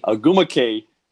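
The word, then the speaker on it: Ogwumike: Nika, I always get her last name wrong. – Agumake.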